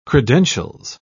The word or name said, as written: credentials